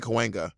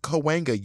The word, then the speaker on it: Cahuenga: It took me a while to get Cahuenga. And LA people are not chill about you mispronouncing the name. They will let you have it.